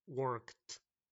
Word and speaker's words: worked: Debes de notar que no se dice walk o work sino que suena una D seca, como una T, walked, para que sea pasado. Walked, worked.